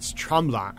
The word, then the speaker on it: Tremblant: And I mean, compared to the West, that's not saying all that much, but it's Tremblant. I think that's how you pronounce it.